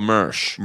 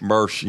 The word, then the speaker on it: Mersch: I like the Messer guy better though, or however you pronounce his name. – Oh, Mersch. – Mersch.